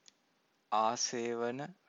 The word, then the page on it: asevana: Pali